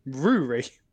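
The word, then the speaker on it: Ruaidhrí: Apparently, Rory. – Rory. – Or Rory. – Rory. – Every single part of that name is silent. The D is silent.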